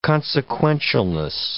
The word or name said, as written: consequentialness